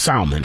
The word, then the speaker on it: salmon: Now, first of all, do y'all pronounce it salmon or salmon? Because Salmon. – It is salmon.